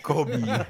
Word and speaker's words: Kobe: Tulisannya Kobe memang. Iya. – Bacanya Kol kol kobi.